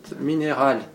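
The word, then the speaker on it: Mineral: Значит, э, в слове минерал ударение такое же, как в слове генерал, да? Значит, минераль пасса.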